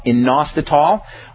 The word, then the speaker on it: inositol: There's a nutrient called inositol, which is kind of like a B vitamin. That's spelled I-N-O-S-T-I-T-O-L, inositol.